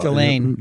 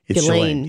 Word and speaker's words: Ghislaine: Ghislaine. – Ghislaine. That's it. – Ghislaine. – I never get that right. – It's Ghislaine.